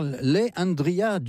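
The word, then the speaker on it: Le'Andria: Alors, Leandria Johnson, Leandria, ça s'écrit L-E-apostrophe et Andrea, est une artiste de gospel encore jeune.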